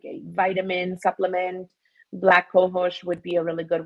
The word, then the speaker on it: cohosh: Black kosho? – Black cohosh. It's How do you spell it? – black COAO. COHOSH, black cohosh.